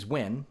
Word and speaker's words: Nguyen: So he was also talking about how people can name their like the first name Nguyen, which is Win, right?